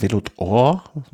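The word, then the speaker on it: Vélo d'Or: Velodor, spricht man das so aus? Velodor? – Ja.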